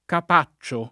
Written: Capaccio

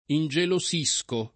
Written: ingelosire

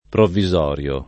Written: provvisorio

